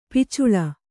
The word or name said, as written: ಪೊಚ್ಚಳ